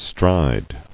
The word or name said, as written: Striding